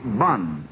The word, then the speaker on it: boun: called bun, spelled B-O-U-N in those days, but pronounced like we pronounce the word bun.